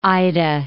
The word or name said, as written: Ida